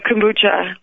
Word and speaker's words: kombucha: My pug, And I may not pronounce this right, kombucha? – No, kombucha, lavender kombucha, yeah.